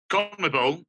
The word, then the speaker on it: CONMEBOL: You are there for CONMEBOL. I don't know if it's a pronunciation of an acronym, but Libertadores. So tell us about the competition, and what you're doing. – So, CONMEBOL is, you said it right, it is the South American region.